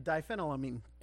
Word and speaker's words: diphenylamine: It's diphenylamine. I don't know if that's right. – You know, you did right. It's a phenylene. Okay. Diphenylamine.